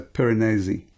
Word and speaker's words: Piranesi: All right, well, I'm going to start off with a book called Piranesi by Susanna Clarke. I'm trying to pronounce it correctly because it's from an Italian name, so Piranesi.